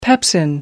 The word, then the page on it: pepsin: American English